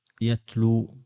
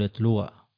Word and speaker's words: يتلو: وإنما تقول نبأ إي يتلو، ولا تقول يتلوى وهكذا.